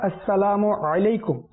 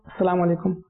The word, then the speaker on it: আসসালামু আলাইকুম: উচ্চারণও ভালোভাবে করতে পারে না, উচ্চারণ আসসালামু আলাইকুম ওয়া রাহমাতুল্লাহি ওয়া বারাকাতুহ এটাতেও মানুষ মানে মুসলমান মুসলমানকে সালাম আলাইকুম সালাম আলাইকুম এটা শর্টকাট করে দিচ্ছে, অথচ উচ্চারণ আসসালামু আলাইকুম আইনের সাথে এটা হচ্ছে না, আরো শর্ট আমি আলে সুন্নাত বলেন যে শর্ট হতে যাচ্ছে, এটা দূরে চলে যাচ্ছে মুসলমানদের।